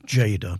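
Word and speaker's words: Tjader: That was Cal Jada, and it's spelt in a very unusual way. It's pronounced Jada, but it's spelt with a T, T-J-A-D-E-R, Cal Jada.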